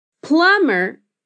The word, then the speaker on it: plumber: Plumber encanador, também é escrito com um B que a gente não pronuncia. Então a gente escreve plumber e diz plumber.